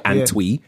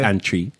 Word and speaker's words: Antwi: And I'm pronouncing it like a real Ghanaian. You might call it Antwi, but it's Antry.